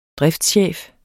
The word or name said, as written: driftschef